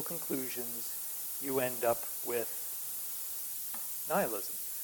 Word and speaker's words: nihilism: Next one is nihilism, or sometimes pronounced nihilism.